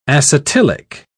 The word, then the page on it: acetylic: British English